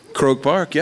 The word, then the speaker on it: Croke Park: I got to see him play at Croke Park. Is that how you say it? – Croke Park, yeah.